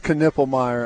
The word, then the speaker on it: Knippelmeyer: I remember someone say, no, it's Knippelmeyer. And I was like, what do you mean? No, it's not. There's no way that's how you pronounce that. It's not possible. But, yeah. Like all the other times in my life, I was totally wrong.